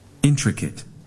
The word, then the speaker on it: intricate: Intricate. – The second time. – Intricate. – Your turn. 3, 2, 1. – Intricate. – One more time. – Intricate. – นักเรียนปลายทางอย่าลืมออกเสียงตามไปด้วยนะครับ So everybody, we put the stress on which syllable? The first, second, or third syllable? Where is the stress? First syllable. So we pronounce it intricate.